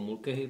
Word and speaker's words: Mulcahy: No, jak si řekl Russell Mulcahy, nebo Mulcahy, protože je to Australan, ověřili jsme si to, jak se to vyslovuje,